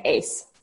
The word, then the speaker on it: Ace: Even in my home country, people do, but it's pronounced Jessica Ace, as in A-C-E.